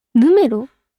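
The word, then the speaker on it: N°21: N ドットって読むと思います。これは N.21 え、なんかでも見たことあります、このこれはこの並びは見たことあるけど、確かに読み方、正解お願いします。え、ヌメロ本当ですか、嘘だ、ヌメロなわけないよ。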